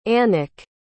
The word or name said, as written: annick